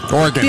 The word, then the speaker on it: Oregon: And you said it right. Oh. – Oregon.